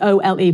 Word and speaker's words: Ole: But I actually think you, there, there's, a, there's a researcher called Ole Dammegård, Ole, like Ollie, O L E, but it's pronounced Ole.